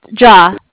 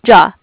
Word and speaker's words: Jia: J-I-A? – Mm-hmm. Yeah. It's, I guess, okay, cool. – If you want to say it, it's probably spelled like J-A-H is how you would sound it out.